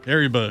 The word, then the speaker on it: Haribo: That was Haribo, or whatever how you say it. – Haribo.